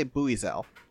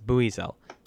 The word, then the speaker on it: Buizel: Because it's like a buoy, yeah. – Yes, that's how it's said in Japanese. I checked Papago before the episode this time. It's Buizel.